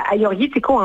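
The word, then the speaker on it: Agiorgitiko: Vous êtes très bonne, parce que pour ceux qui nous écoutent, le Aioritiko, en fait, c'est deux G, mais les G, on les prononce comme les Y.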